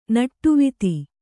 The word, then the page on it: ನಟ್ಟುವಿತಿ: kannada